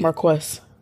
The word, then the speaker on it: Marques: Now, Marcus. Mm-hmm. Marcus. – Marquise. – We Marquess. – That's how it's spelled.